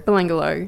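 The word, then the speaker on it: Belanglo: Now, I don't know if I'm pronouncing this right. People pronounce it differently everywhere. The Belangelo or Belangelo? – Belangelo.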